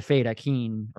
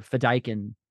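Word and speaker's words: Fedaykin: Because he was one of the Fae Dakin, or Fedaikin, I think is how it's actually supposed to be pronounced.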